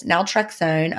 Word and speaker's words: naltrexone: The subject is question about naltrexone.